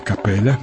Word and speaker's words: Капеля: Доминика Пари, Эрве Капеля, его фамилия начинается с буквы С, которая читается как К.